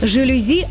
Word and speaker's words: жалюзи: Для большинства не секрет, что слово пришло из французского языка, где ударение всегда ставится на последний слог, то есть «жалюзи», никак иначе.